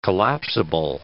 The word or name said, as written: collapsible